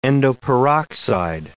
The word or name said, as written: endoperoxide